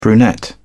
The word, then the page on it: brunette: British English